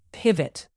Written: pivot

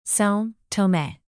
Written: sao tome

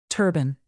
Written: turban

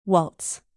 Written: waltz

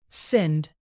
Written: sinned

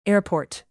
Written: airport

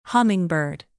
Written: hummingbird

